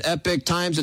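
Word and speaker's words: Epoch Times: They do pronounce it Epic Times.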